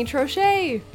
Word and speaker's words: Troche: Today I have a guest I'm so excited about, hairstylist, makeup artist, Brittany Troche.